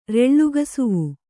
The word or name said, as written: ರೆಳ್ಳುಗಸುವು